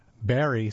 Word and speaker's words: Barre: Barry, spelled B-A-R-R-E, but pronounced Barry, is a small city in the bowl of a steep valley in the northern reaches of a lightly populated mountainous state.